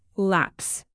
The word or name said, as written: lapse